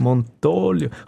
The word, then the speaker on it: Montolio: Gott, Betonung auf dem O. – Kann man das nicht? Gesagt? Montolio.